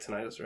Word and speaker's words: tinnitus: Because he was like in a car crash when he was a kid, which is why he has tinnitus, right? – I'm saying it right. Tinnitus, right? – Tinnitus.